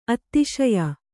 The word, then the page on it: ಅತ್ತಿಶಯ: attīaya